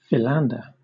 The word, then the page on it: philander: British English